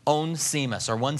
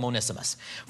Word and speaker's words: Onesimus: Verse 10, "I appeal to you for my son Onesimus, I guess we'll call him today. How you pronounce it, people pronounce it differently, but we'll call him Onesimus. "For my son Onesimus."